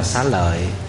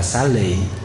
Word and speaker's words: xá lợi: Chữ xá lợi này nguyên tiếng Phạn đọc là Sari. Thì cái chữ Sari này người Trung Quốc mới phiên âm là xá lợi, hay còn đọc là xá lị.